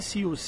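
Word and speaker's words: Zeus: Z U S नाम है लेकिन उसको प्रोनाउंस किया जाता है सीयूस सीयूस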